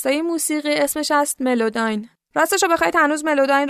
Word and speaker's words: Melodyne: ملوداین اینجوری تلفظ میشه: MELODYNE.